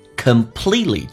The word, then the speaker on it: completely: Completely 这个单词，你可以说 completely，但是 T 跟 L 一般在一起的时候，基本上 T 不发音，就好像 exactly 可以说 exactly， absolutely 可以说 absolutely， completely， all right，完全的。